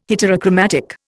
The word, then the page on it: heterochromatic: Indian